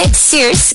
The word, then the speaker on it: Sirs: Básicamente, Sears, que se escribe S-I-R-S,